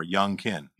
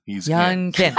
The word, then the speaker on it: Youngkin: I want to pronounce it like Young, like Carl Jung. – Like, just think of him as a rapper, Youngkin. – Youngkin.